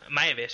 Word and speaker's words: Maeve: mucho más la trama de, los científicos y los ingenieros y, y cómo el, sobre todo los personajes como Dolores o maíz que... maíz se llama la... Sí, tiene un nombre muy raro. Maeve, sí, Maeve. Sí, Maeve, lo he dicho bien.